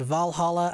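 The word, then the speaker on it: VA-11 HALL-A: One of them's called Valhalla, but it's spelled funny. It's called Valhalla, a cyberpunk bartender action game. – Excuse me? – It's VA-11HALL-A.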